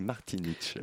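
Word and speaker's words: Martinić: J'aurais dit Ivor Martinic, mais je te fais confiance sur les recherches. Bah oui, je euh. suis quand même d'origine croate, donc euh, je au moins savoir prononcer Martinich.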